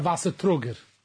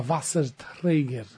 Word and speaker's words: Wasserträger: You don't either know Yiddish, because it's not a Wassertrager. That's not the way you say it. How do you say it? Do you know how you say it, Elchanan? – I don't know Yiddish. – It's a Wassertrager. Träger. Someone who trugs Wasser is a Wasserträger.